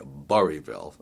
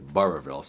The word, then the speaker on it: Burrillville: So many times you'll hear people drop the two middle L's and just say Burrillville, and it's actually Burrillville.